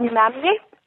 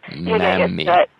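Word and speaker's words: mämmi: Okay, so my scariest food experience has been with a Finnish Easter dessert called mammi. – And, oh, wait, how do you pronounce it again? – It's called mammi. – Mammi. – Yeah. it's a bit of a weird pronunciation, but mammi.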